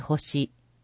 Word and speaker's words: 地球: 危険物安全週間、令和 6 年度危険物安全週間推進標語、次世代へつなごう、無事故と青い星。星という字は地球と書いて星と読む。